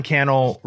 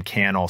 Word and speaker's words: Cannell: So Sean Cannell rhymes with YouTube channel on Instagram and all the social media platforms. That's S-E-A-N for Sean. And then my last name, Cannell, C-A-N-N-E-L-L.